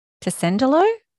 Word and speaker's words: Tessenderlo: So give us a little bit of background of what it was actually like for you growing up in, is it Tessenderlo? Is that, how do you say it? – Yes.